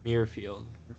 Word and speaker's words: Muirfield: He can't pronounce Muirfield. – Muirfield. – There you go.